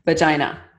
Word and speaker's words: vagina: Everybody, listeners, vagina. – Vagina. – It rhymes with the Canadian city, Regina.